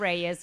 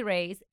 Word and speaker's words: Reyes: Yes, my Instagram handle is at Suni SUNI and then the underscore Reyes, because that's how white people pronounce my last name instead of Reyes. So Reyes at Rays of Sunshine. – Then, yeah, that's me, Suni Reyes.